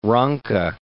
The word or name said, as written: Ranke